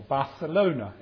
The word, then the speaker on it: Barcelona: We were in Barcelona on one occasion, or Barcelona, as they pronounce, and we're trying to read a map, and a lady came to help us.